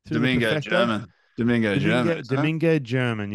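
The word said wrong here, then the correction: Domingo Germán: No, Domingo Herman. Let's say it right. Right. – Herman. Right. Domingo Herman.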